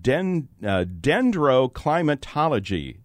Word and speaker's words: dendroclimatology: Tell us about the science of dendroclimatology.